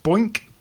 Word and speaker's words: BOINC: There's this thing called, I think it might be pronounced Boink, B-O-I-N-C, which you can use.